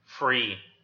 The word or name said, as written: free